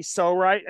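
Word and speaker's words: Psorite: There's this company called Sorite. It's spelled P-S-O-R-I-T-E. P-S-O-R-I-T-E, so right.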